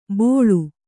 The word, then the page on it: ಬೋಳು: bōḷu